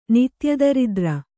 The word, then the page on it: ನಿತ್ಯದರಿದ್ರ: nitya daridra